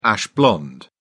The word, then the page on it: ash blonde: British English